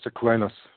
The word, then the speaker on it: Aquinas: Yeah, Thomas Aquinas.